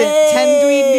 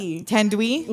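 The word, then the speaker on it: Thandiwe: Tandwi? – We'll, like, actually listen to the pronunciation. But, yes, there's a W in it now.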